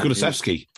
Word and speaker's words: Kulusevski: Kulusevski. – Kulusevski, who was quality but wasn't fit to start.